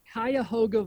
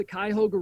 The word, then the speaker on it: Cuyahoga: Cayuga Valley Church. – Oh, that's not even close. Yeah, a whole syllable I left out.